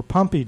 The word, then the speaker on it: Pompe: So why don't we begin our conversation with the clinical trial of gene therapy for Pompe disease, and I hope I'm pronouncing that correctly.